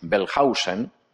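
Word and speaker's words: Wellhausen: un alemán que se llamaba Julius Bellhausen, la W en alemán se pronuncia como V fuerte, no como U.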